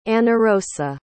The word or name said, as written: anarosa